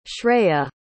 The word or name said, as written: shreya